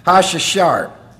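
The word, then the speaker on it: Hashshahar: For the choir director upon Hijaleth Hashashar, that's how you pronounce that, Hashashar.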